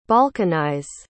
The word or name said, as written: balkanize